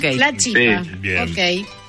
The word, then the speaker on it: chipa: Bueno, ¿podemos dejar en claro entonces la pronunciación correcta es la chipa? – Sí. – Hay que decir así, está mal dicho chipá.